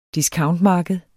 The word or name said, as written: discountmarked